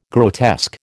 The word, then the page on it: grotesque: American English